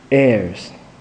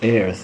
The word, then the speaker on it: heirs: Paul said, since you are his children, he has made you also heirs. I didn't know this word pronounced heirs. I was pronouncing heirs all the time, but I noticed that I was wrong.